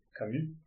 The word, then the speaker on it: Camus: Hoje nós vamos falar do livro O Estrangeiro, de Albert Camus, Camus, Camus, né? Pronúncia em francês.